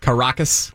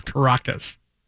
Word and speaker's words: Caracas: I can't pronounce anything tonight. – Caracas? – Caracas.